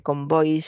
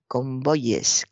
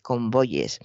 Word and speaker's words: convoyes: No se dice convoys, se dice en plural convoyes, convoyes. Convoyes, eh, pues por ejemplo, como ley, que se dice leyes, o buey, que se dice bueyes, o como hay, que se dice ayes.